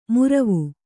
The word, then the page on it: ಮುರವು: muravu